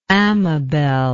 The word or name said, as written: amabelle